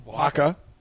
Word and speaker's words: Oaxaca: O-A-X-C-A-O. – Waka.